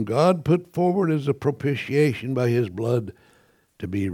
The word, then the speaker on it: propitiation: When I first started preaching, my sister says, you pronounced that wrong because I said propitiation. It's propitiation, and I learned that from her.